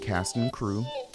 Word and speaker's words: Cast and Cru: Again, Cask and Crew. And Cask and Crew is spelled Cast and C-R-U.